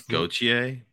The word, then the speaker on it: Gauthier: The Gauthier, right? Is that how you say it? Did I make that up? – I think it's Gauthier, but Gauthier. Right. I just like Frenched it up real hard.